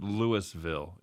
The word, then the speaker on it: Louisville: Oh, they pronounce it Louisville in Colorado.